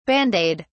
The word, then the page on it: bandaid: British English